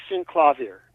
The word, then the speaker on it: Synclavier: Talking about this album, Devil in the Drain, how do you pronounce the name of the primary instrument you play on this? Is it synclavier? – It's synclavier, yeah. Is how they would pronounce it.